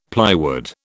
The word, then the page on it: plywood: British English